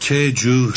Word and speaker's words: Jeju: Anyway, back to San Francisco and the island of Chehu. Is that how it's pronounced? – Jeju.